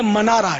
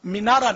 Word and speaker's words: منارا: خیر تو یہ گنبد شریف بنا, پھر منارے بنے, یا غلط العام ہے مینارے بولتے ہیں ہم لوگ, ہے منارا, میم پر زبر آتا ہے عربی میں, منارا میں زنہ بھی بولتے ہیں اس کو, تو غالباً عربی لفظ منارا بھی. بہرحال یہ کہ وہ لفظ تلفظ صحیح جو ہے نا یہ منارا ہے لغت کے اعتبار سے, مینارا نہیں.